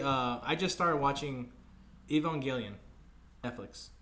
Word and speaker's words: Evangelion: Because I always say, I look at it like Evangelion. – Yeah, Evangelion. – Yeah, okay. That's how you really say it.